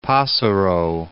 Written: Passero, Cape

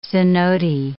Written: cenote

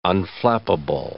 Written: unflappable

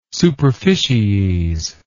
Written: superficies

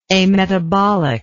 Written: ametabolic

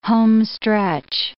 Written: homestretch

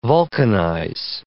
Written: vulcanize

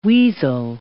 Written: weasel

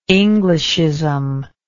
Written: englishism

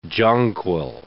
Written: jonquil

